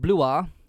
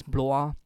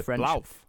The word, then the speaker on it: Blois: So Stephen of Blois, B-L-O-I-S, Blois. – I would have said Blouf. – Yeah, I googled the pronunciation of this one, and apparently it's like Blois. – Is it French? – Blois, yeah.